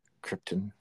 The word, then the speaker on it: Krypton: Or if you're Marlon Brando, you pronounce it Krypton. – Krypton.